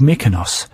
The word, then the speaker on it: Mykonos: Well, I've got a really nice song by Fleet Foxes called Mykonos.